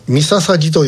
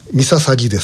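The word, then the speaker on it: 御陵: ミササギと読みます。ミササギです。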